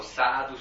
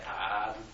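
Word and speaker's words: sadhu: Todas las S se convierten en T para empezar. Sí. So, sadu, sadu, sadu sería tadu, tadu, tadu.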